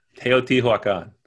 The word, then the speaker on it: Teotihuacan: Teotihuacan. – Oh, very good. Pretty close. Pretty close.